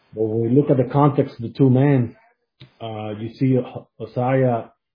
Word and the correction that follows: Uzzah: how do you say it? – Uzzah. – Uzzah. Yeah, Uzzah.